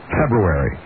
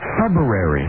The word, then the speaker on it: February: See, now says, I think pronounces it correctly. – February. – You say February, and then I really, probably for most of my life, have said February. – Mm-hmm. Most people do. – Well, you might as well say library then. Let's go to the library.